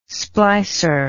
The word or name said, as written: splicer